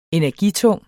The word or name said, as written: energitung